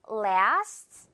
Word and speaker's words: lasts: Nothing lasts forever. 이런 말 통째로 가끔 합니다. 그 무엇도 영원하진 않아. Nothing lasts forever. 이럴 때 lasts forever 아니고요. la만 정확하게 발음하고 sts.